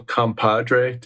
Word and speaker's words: compadre: Yeah, so, Joe, I feel like we've made a spiritual compadre.